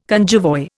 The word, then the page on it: cunjevoi: Indian